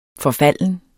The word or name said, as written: FORFALDENT